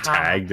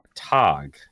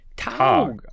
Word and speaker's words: Tag: It's not hard. Tog. – Tog. – With a long A. – Tog.